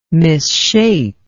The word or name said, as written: misshape